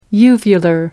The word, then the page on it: uvular: American English